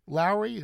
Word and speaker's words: Lowrie: Lowrie, is that? – You are. That's, yeah. Yes, correct.